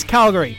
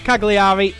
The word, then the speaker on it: Cagliari: The other in which we drew 1-1 away at Serie A side, whose name I now know how to pronounce. It's Calgary. It's not Cagliari. It is Calgary.